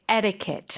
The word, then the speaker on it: etiquette: So, of course, it's American English, so we would say etiquette. – Etiquette. – Flap that T.